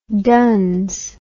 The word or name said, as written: dons